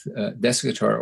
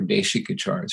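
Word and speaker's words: Desikachar: And my initial meeting with Deskachar, or Deshikachar is probably how you correctly pronounce it,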